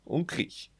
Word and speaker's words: Unkrich: Unkrich.